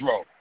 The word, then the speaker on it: Roll: Is it Roll? – Yeah, it's Roll. The E is silent.